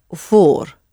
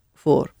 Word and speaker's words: voor: The word for is voor, voor. Spelled V-O-O-R. So the V is really pronounced like an F.